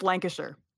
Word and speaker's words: Lancashire: So if you see the word Lancashire, it's actually pronounced Lancashire. – Yeah, right. – Even though it's spelled Shire. So it's Lancashire. Mm-hmm. So anything like that, it's a sure.